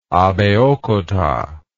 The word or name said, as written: abeokuta